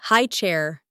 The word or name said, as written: high chair